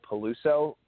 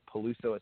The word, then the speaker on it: Peluso: And my name is Michael Peluso. Peluso is spelled P-E-L-U-S-O.